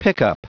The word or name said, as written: pickup